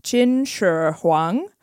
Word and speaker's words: Qin Shi Huang: And I listened to a pronunciation of this about 50 times, and I'm still going to botch it. But his new name is Qin Shi Huang, which means first emperor of Qin.